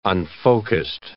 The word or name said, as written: unfocussed